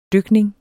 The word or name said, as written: dykning